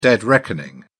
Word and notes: dead reckoning: British English

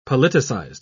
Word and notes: politicized: British English

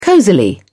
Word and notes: cozily: British English

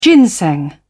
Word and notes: ginseng: British English

